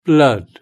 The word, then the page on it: blood: British English